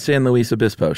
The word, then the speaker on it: San Luis Obispo: Did I say that right? – I don't know. You just sound so sure of yourself. – Well, you lived in California, so I didn't know if I just butchered it or not. San Luis Obispo, sure.